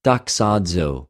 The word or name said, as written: δοξαζω